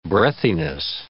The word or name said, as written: breathiness